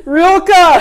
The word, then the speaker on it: Rilke: But I really think waiting and oh, you know, Wilke, am I pronouncing? No, don't Wilke. I'm always mispronouncing his name. Rilke.